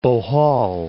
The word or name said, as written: Bohol